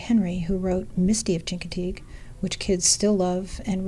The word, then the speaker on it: Chincoteague: It's actually a story of my sister and I who went down to Chincoteague, where you pronounce Chincoteague with, like, Chicago.